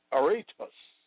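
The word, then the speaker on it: aretos (ἄρρητος): The Greek word for unspeakable is aretos, pronounced aretos, meaning unsaid or inexpressible.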